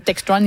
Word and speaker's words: Textron: Oui, ce sont des informations qui ont été obtenues par notre collègue Thomas Gerbet qui montre que Bell Textron, qui possède Textron, il le prononce comme ça en anglais, évidemment.